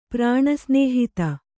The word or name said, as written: ಪ್ರಾಣಸ್ನೇಹಿತ